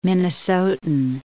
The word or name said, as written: Minnesotan